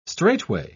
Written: straightway

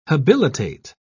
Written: habilitate

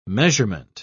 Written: measurement

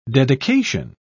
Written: dedication

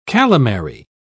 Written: calamary

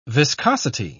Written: viscosity

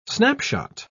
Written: snapshot

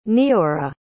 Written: neorah

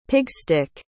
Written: pigstick

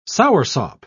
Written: soursop

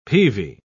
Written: peavy

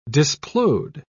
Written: displode

